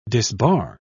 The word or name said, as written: disbar